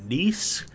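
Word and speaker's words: Kneese: I am your host, Sean Neese. That's K-N-E-E-S-E. The K is silent. And no, it's not like niece to nephew.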